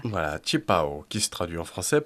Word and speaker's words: 旗袍: Mais maintenant, on a tendance à utiliser directement sa prononciation chinoise, c'est-à-dire qu'on n'utilise plus robe fondue des deux côtés, on dit directement qi pao.